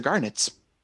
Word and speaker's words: garnit: Same pronunciation, but more like knit small insect kind of. – Insects. So we have to go bowling again? – G-A-R-G-N-E-T-S? – G-A-R-N-I-T. – Okay. Oh, that kind of knit. I heard Nat. – Yeah, it's all pronounced the same way.